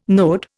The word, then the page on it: node: Indian